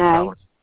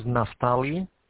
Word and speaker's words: Naftali: Naftali. – Oh, I'm sorry. – No, not the applicant, the agency. – So my name is Naftali.